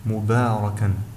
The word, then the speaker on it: مباركا: ثم في حرف الباء أيضا في الاستفال، في حرف الباء حرف مستفل، وقد فخمته في كلمة مباركا لوجود حرف الراء الذي هو حرف مفخم في هذه الحالة ويجر حرف الباء إلى التفخيم، وبالتالي يجب الحذر عند التقاء حرف مستفل وحرف مفخم، فقد يجر الحرف المفخم الحرف المستفل إلى التفخيم، وننطق الكلمة هكذا مباركا.